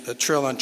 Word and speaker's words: Trilon: One of the ways to remember how to pronounce Trilon is it rhymes with chillin.